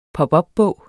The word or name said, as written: pop